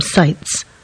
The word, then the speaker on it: Seitz: This is Caroline Seltz. I think that's how you say her name. Seitz. Do apologise. S-E-I-T-Z. Seitz, I think that's how you say it.